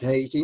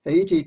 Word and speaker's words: Haiti: Most people say Haiti, I use the Spanish pronunciation. On the western end of the island of Hispaniola in the Caribbean Sea. The interesting thing about Haiti